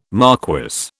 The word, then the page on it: marquess: British English